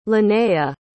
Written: linnea

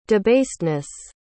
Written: debasedness

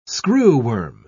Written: screwworm

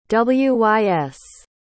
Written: wys

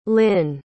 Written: lynn

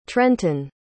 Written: trenten

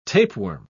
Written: tapeworm

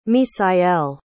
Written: misael

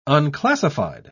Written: unclassified

